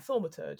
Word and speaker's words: Thaumaturge: You've got Protector, you've got Scholar, and then I'm going to say this wrong, Thaumaturge. I think that's how you pronounce Thaumaturge? – It. Thaumaturge? Yeah, Thaumaturge. Yeah. Yes. – Like Thaumaturgy.